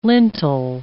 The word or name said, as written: lintel